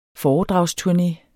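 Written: FOREDRAGSTURNÉ

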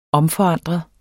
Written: omforandre

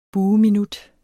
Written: bueminut

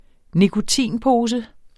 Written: nikotinpose

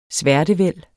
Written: sværtevæld

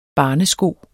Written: barnesko

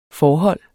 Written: forhold

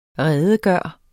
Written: redegøre

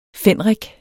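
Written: fændrik

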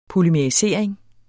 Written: polymerisering

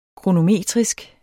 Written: kronometrisk

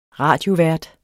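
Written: Radiovært

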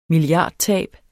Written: milliardtab

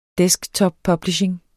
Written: desktoppublishing

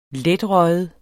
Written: letrøget

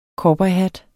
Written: COWBOYHATTEN

